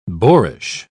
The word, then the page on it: boarish: British English